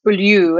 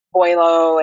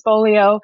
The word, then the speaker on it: Beaulieu: So my last name is Beaulieu, and I was explaining to Drew, it's very Americanized because it actually is French. It would be pronounced Beaulieu, and it means beautiful place. And people always mispronounce my name, Beaulieu, and say different things like that. So I would correct them and say, no, it's Beaulieu.